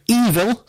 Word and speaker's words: Eville: The band is called Evil. That's spelled E-V-I-L-L-E.